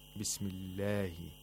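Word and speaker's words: Bismillahi (بسم الله): Notice how the word Allah is pronounced. Bismillahi. Bismillahirrahmanirrahim. The Lam of the Allah is pronounced with tarqiq, with thinness, not with thickness, which would be taghlil, because before the majestic name of Allah, we have a letter with a kasra.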